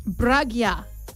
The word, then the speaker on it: Brugger: am I pronouncing your name correctly? – No. – Ah, good. – Okay, what is your name? – It's Brugger. – Brugger. – Yes. – Okay. All right. – Give it a go, Tim. – B-U-R-G-J-A-R.